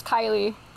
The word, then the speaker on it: Kailee: It's actually pronounced Kailee. – Kailee? – I know it's normal for people to pronounce it Kailee, because that's like the mainland pronunciation.